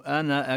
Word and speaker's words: أنا: بعض القراء يعني إذا وصل كذلك يعني يثبت الألف يمد المد المنفصل، أما برواية حفص عن عاصم هذه أنا في لو جاء بعدها همزة مفتوحة أو غير ذلك أو غير يعني فتحة لا توصل، لا لا لا تلفظ في الوصل إنما تسقط في الوصل.